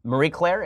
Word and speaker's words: Marie Claire: So there's an article that's just astonishingly great in its stupidity from Mary uh, from Marie Claire. Is that how it's pronounced? Okay, good. Uh, and uh, it's it's all about how skinny eyebrows are apparently problematic now.